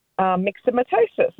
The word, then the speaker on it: myxomatosis: Yeah, or if it was from myxomatosis.